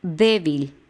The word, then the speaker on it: débil: Débil, termina con L, son dos sílabas y el acento va en la E, débil. La última sílaba sería vil y la penúltima va a ser d.